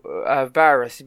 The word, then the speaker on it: Veris: And you see Picard on his new ship, the USS, I have to pronounce this now, Varis, V-E-R-I-S,